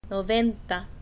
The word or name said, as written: noventa